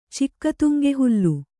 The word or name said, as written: ಚಿಕ್ಕತುಂಗೆಹುಲ್ಲು